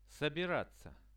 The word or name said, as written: sobiratsia